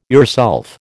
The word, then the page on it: yourself: American English